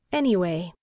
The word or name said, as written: anyway